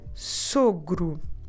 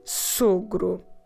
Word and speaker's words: sogro: The word for father-in-law is the word sogro. The father-in-law, o sogro. How do we spell it em português? S-O-G-R-O, sogro.